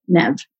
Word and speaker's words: Neve: Neve is not a name until my parents came up with it. And it's my mother's maiden name. She's Dutch. And they decided to pronounce it Neve in Canada.